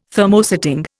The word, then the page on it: Thermosetting: Indian